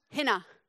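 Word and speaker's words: hinna (ἵνα): It's pronounced hinna. Everyone say hinna. – Hinna. – Hinna, yes. If I was going to spell that in English letters, it would be I-N-A with a silent H on front.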